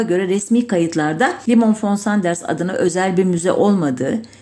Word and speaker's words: Sanders: Daha önce söylemiş miydim bilmiyorum ama Sanders Sanders diye okunur Almanca'da ama Z ile yazılmaz.